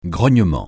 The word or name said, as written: grognement